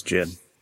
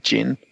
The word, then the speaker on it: Jyn: I think it's Jyn. – Yeah, Jyn. At New York, they had a gin drink at the Hasbro panel. which was spelt with a Y, so I'm assuming they were punning on her name, so I'd assume Jin.